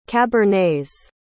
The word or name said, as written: cabernets